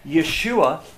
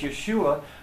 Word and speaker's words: Yeshua: In the Jewish home, saying that I have met Yeshua, because in Hebrew it's Yeshua, but Orthodox Jews call him Yeshu. And it's actually pronouncing his name as an insult, and it says that name Yeshu means may he be forgotten forever.